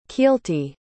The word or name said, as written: keelty